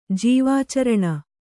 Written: ಜೀವಾಚರಣ

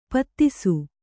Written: ಪೊದೆಸು

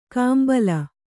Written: ಕಾಂಬಲ

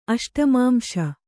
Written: ಅಷ್ಟಮಾಂಶ

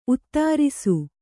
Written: ಉತ್ತರಸ

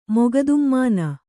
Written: ಮೊಗದುಮ್ಮಾನ

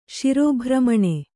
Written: ಶಿರೋಭ್ರಮಣ

